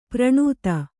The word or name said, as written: ಪ್ರಣುತ